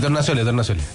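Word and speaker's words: Torgnascioli: Ya, escuchemos entonces a Tornaccioli, ¿así está bien dicho? No lo dije mal de nuevo. – Franco. Dígale Franco más fácil. – No, que... – Sí, Tornaccioli. – Perfecto.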